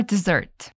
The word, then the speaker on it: dessert: Cheesecake for dessert. – For dessert significa como sobremesa. Olha só, cuidado com a pronúncia. Dessert é sobremesa. Não confunda com desert, que é deserto, ok? Dessert, sobremesa. Desert, deserto.